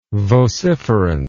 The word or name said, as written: vociferance